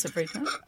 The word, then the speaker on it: sofrito: How do you say the sofrito? – Yes, sofrito.